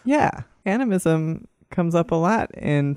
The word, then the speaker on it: animism: I get animism? – That. – Is that how you say it? – Animism.